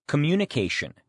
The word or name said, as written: communication